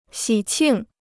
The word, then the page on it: 喜庆: xǐ qìng